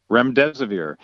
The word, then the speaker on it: remdesivir: Now, this other one that's out there, I can't pronounce it, remdesivir or something? – Yeah, remdesivir.